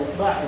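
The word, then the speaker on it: isba' (إصبع): Wa sahha an taqul usbu'. Hada usbu', usbu', usbu', usbu', usbu'. Ya, ini pengucapannya ada tiga. Isba', usbu', atau isbi'. Kullu dhalika sahih.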